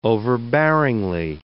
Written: overbearingly

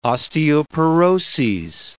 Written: osteoporoses

